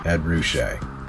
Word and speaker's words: Ruscha: If you know anything about pop art, you're familiar with that name, E-D-R-U-S-C-H-A. If I'm not pronouncing that correctly, I'm sorry, Ruska. But I'm pretty sure it's Ruska. – I mean to say, Ed Ruscha.